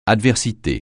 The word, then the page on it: adversité: French